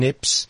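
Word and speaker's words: Niépce: Now, that is the correct French pronunciation, Nips, but in English, it's N for no, I for India, E, P for Peter, C, E. And it's pronounced Nips, but it's N-I-E-P-C-E.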